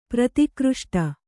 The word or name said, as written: ಪ್ರತಿಕೃಷ್ಟ